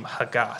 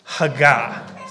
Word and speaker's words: hagah: but they're all that same hagah. So let's say that word together. Hagah. Say it again. Hagah. Okay. Hagah is spelled H-A-G-A.